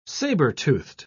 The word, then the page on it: sabre-toothed: British English